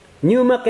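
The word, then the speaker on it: pneuma (πνεῦμα): It's spelled P-N-E-U-M-A, pneuma.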